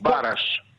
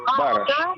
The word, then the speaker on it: Бараш: Бараш, или... Бараш. Ударение на первый слог. Бараш. – Нет, Б-А-Р-А-Ш-А, правильно? Бараш.